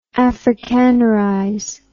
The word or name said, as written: afrikanerization